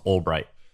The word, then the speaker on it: Ulbricht: So there's this guy named Ross Ulbricht. Am I saying that right, you think? – I think so. It could be Ulbricht, but I think it's Ulbricht.